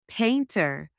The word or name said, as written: painter